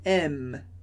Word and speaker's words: aimes: Mas, atenção, s e e no final da palavra a gente não pronuncia. Por isso, tu aimes.